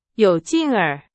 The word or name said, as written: 有劲儿